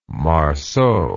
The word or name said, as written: marceau